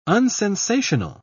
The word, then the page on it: unsensational: British English